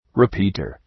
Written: repeater

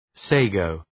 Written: sago

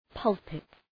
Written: pulpit

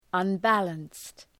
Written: unbalanced

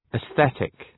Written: atheistic

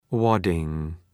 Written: wadding

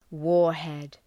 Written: warhead

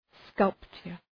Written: sculpture

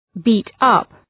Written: beat-up

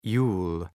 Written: Yule

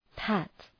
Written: pat

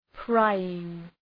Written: prying

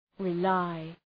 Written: rely

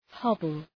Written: hobble

